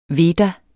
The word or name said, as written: veda